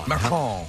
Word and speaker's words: macaron: Macaron.